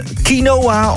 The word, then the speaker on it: quinoa: Quinoa, of quinoa zoals je het ook kunt uitspreken.